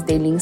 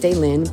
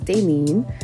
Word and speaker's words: Dailyn: My name is Daylene Santana, and phonetically it's pronounced Daylin. It's spelled D-A-I-L-Y-N. So you think it's pronounced Daylin, but it's actually pronounced Daylene.